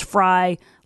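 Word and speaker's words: Frey: Aaron Chadbourne just texted me, it's pronounced Fry like French fry.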